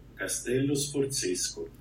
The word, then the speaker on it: Castello Sforzesco: Castello Sforzesco. Exactly. Castello Sforzesco.